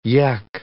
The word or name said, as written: yecch